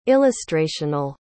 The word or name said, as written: illustrational